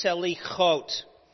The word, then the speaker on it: Selichot: So the term is Selichot, is how it's pronounced.